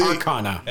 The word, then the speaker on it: Arcana: Arcana. – Their Kana. – No, the Arcana. – Yeah, whatever. I don't give a shit. – They all said it wrong.